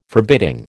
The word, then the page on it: forbidding: American English